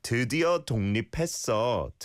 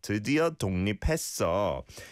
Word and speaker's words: 드디어 독립했어: I can hear you pronouncing the 기억 there on 독, but you don't need to, right? 드디어 독립했어. 드디어 독립했어.